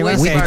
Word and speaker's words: Whitford: Brad Whitford. – ¿Brad? – Whitford. – ¿Lo está pronunciando bien, seguro? – Sí, Whitford.